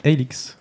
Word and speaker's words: Hylics: D'accord, et ça s'appelle Helix. Helix, ouais. Ça s'écrit H-Y-L-I-C-S.